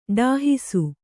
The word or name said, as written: ಡಾಹಿಸು